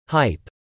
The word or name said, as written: hype